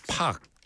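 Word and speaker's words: Paak: 자, 9629번으로 앤더슨 팍. 앤더슨 팍. 사실 이거 PAAK라고 써 있잖아요. 그래서 이 친구들은 뭐 팩이라고도 발음하고 팍이라고도 발음하는데 우리는 알잖아요. 박이라는 게 이게. 이게 박이라는 걸 알. 셀이 박하고 똑같은 거예요.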